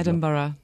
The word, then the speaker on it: Edinburgh: And do I get the pronunciation right there? – Yeah. – Say it for me in your good Edinburgh. – Note. Edinburgh. – Edinburgh. – Edinburgh. – Edinburgh. Roll that R. – Edinburgh. It's like B-U-R-R-E.